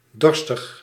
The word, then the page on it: thirsty: American English; /ˈθɜː(ɹ)st.i/